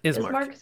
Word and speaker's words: Ismark: Ismark? – Ismark.